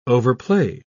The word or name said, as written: overplay